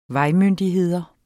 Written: vejmyndigheder

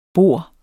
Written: bor

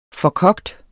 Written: forkogt

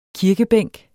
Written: kirkebænk

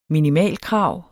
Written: minimalkrav

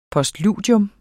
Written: POSTLUDIER

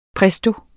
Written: presto

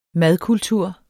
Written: madkultur

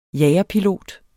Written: jagerpilot

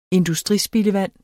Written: industrispildevand

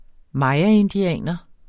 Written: mayaindianer

